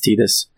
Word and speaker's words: Tidus: Tidus or Titus. It's actually pronounced Tidus.